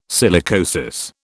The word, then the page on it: silicosis: British English